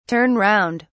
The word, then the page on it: turn round: British English